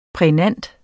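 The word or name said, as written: PRÆGNANTE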